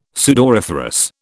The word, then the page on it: sudoriferous: British English